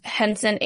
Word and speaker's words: Henson: My last name is spelled Henson, H-E-N-S-O-N. – Got it, okay. And I do note on your application it is S-O-N, so I think we're good.